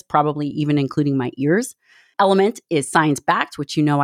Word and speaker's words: LMNT: As you know, Element is an elite hydration pack. So it's spelled LMNT, but it sounds like Element.